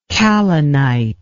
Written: kalinite

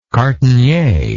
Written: cartonnier

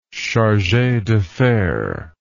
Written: charge d affaires

